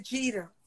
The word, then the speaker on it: Jeter: Like, you mentioned Regina Bell, Genovia Gita. How did you pronounce her last name? – Genovia Jeter. Yeah. Gita, okay.